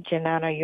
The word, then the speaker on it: Jnana: That's spelled J-N-A-N-A, Janana Yoga, 97 Bond Street in Brooklyn, 11217.